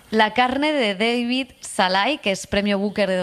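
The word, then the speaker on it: Szalai: Pues me traje un libro que se llama La carne, que es de David Snars. Ahora pronunciaré bien su nombre. David Salai.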